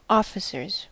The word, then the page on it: officers: American English